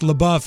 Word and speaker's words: LaBeouf: And LaBeouf is pronounced LaBeouf. It means in French, it means the beef.